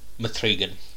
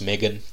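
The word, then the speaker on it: M3GAN: It's called Mithrigan. – Mithrigan. Mithrigan? – I guess it's supposed to be pronounced Megan. – Oh, okay, yes. – But the E's a three.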